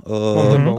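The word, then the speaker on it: Montembeault: Ako sa vyslovuje Montebo? – Montebo.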